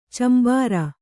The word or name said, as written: ಚಂಬಾರ